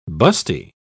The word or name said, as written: busty